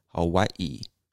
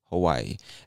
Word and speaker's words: Hawaii: I've heard many people from the islands of Hawaii, they pronounce it Hawaii. Like there's emphasis on that last I, Hawaii. But again, most of us just say Hawaii.